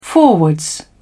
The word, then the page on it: forwards: British English